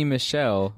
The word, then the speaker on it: Michel: So I want to be funny, and since I never pronounce names incorrectly, G2, it should say G2, pick 5, you had Sonny Michael. – Right. Sonny Michel was my pick.